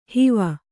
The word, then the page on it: ಹೂವು: hūvu